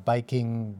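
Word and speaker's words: biking (バイキング): But there is no V sound in Japanese, so it's turned into a B sound. And it's there are syllables. You don't have a consonant without a vowel after it in Japanese, except for a few exceptions. So okay. – So you can't just have Viking. You have to have biking something. So biking.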